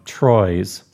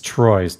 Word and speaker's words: Troyes: So the pronunciation of this is Troy. – It's Troy's. T-R-O-Y-E-S. Don't even get me going with these weird pronunciations. I took a lot of flack for this. It is Troy's.